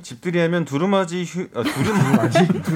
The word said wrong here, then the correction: 두루마리: 두루마지 뭐예요? 두루마리 휴지 사갈게요, 님.